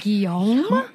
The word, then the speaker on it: Guillaume: Guillaume. – He rhymes with salami.